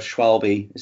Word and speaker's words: Schwalbe: I've always been a fan of Schwalbe.